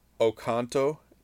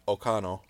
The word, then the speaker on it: Oconto: A lot of people, though, with the Skansen accident will pronounce it Ocano. – Ocano, yeah. – So just completely leave out the T.